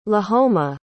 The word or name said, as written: Lahoma